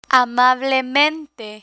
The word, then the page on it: amablemente: spanish